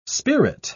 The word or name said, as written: spirit